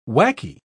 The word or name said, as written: wacky